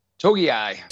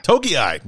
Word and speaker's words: Togiai: How do you pronounce that one? Togiai? – Togiai. – Togiai.